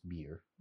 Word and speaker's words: bier: Written B-I-E-R. – Oh, okay. – I think it's pronounced beer. Beer. Not beer, like beer.